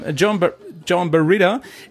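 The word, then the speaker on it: Joan Barreda: Jo, so wird das ausgesprochen. – John Barreda, er hat einen ersten Platz geholt und zwar vor Sam Sunderland von GasGas, der Teamkollege von Sanders.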